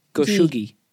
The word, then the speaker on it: goshugi: Couples in Japan receive Goshuji? – Goshuji. – Goshuji. Goshuji. – Goshugi? I think it's a gi. Goshugi.